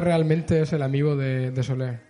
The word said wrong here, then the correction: Solaire: ¿O cómo se dice? – Ah, de Solaire. Solaire.